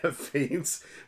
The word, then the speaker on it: Fiennes: Yeah, Ralph Fiennes.